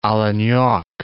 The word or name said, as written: Olenek